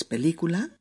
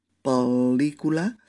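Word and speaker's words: pel·lícula: Eh, es, es, en catalán son dos L's separadas por un puntito en medio. Y se pronuncia eso, como dos L's consecutivas, ¿no? Por ejemplo, película, es película, este pel, ¿veis? Peli, película, o solicitud,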